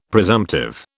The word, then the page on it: presumptive: British English